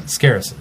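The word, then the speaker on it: Skarasen: Scarison? Is that how they pronounced it? – Scarison.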